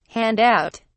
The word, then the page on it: hand out: British English